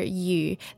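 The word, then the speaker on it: yu (余): The written character yu that is used in this greeting is the character meaning surplus or more than, and its pronunciation is the same as the character for fish, yu.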